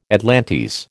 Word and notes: atlantes: American English

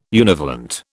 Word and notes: univalent: British English

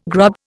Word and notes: grub: Indian

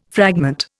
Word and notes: fragment: Indian

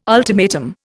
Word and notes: ultimatum: Indian

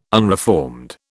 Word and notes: unreformed: British English

